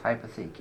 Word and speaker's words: Hypothec: I heard there was one called Hypothec or something. – Hypothec. Pronounced Hypothec, yes.